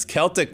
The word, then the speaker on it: Celtic: That's Celtic with a hard K.